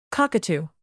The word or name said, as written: cockatoo